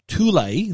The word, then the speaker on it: Thule: That's how I've been told to pronounce this name. Spelt out, it's T-H-U-L-E, which a lot of people may think is Thule. But according to Thule themselves, Thule is how you pronounce it, even though it's spelt T-H-U-L-E.